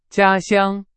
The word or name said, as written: 家乡